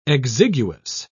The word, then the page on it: exiguous: British English